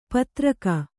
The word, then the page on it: ಪೈತೃಕ: kannada